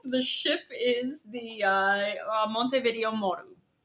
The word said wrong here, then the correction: Montevideo Maru: Whatever you said. The big... – Montevideo Maru. I said that wrong.